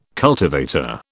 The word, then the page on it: cultivator: British English